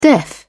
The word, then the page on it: deaf: British English